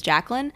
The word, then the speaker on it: Jaclyn: I'll be like J-A-C. I go by Jackie, guys, but my real name is Jacqueline, but it's spelled J-A-C-L-Y-N, and people will be like, oh, okay, J-A-C-K-Q-L-I-N-E, and it's like, where did you get all these extra letters Jacqueline.